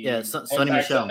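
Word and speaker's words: Sony Michel: And speaking of, I think they pronounce his name as Sony Mitchell, or I think I said his name right. If not, forgive me. He's no longer with the team, and he Sony Michelle.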